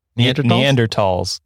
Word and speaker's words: Neanderthals: Neanderthals. – Neanderthals. – It's the, same, it's the same thing. You just don't pronounce the TH. It's just well, talls.